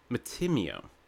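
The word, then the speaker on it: Mattimeo: Oh, sorry, but if I say the name Matamio, I assume, like, oh yeah, that was baptized. – Like, that child was baptized. – Could be... – Matimio. I like the Italian pronunciation.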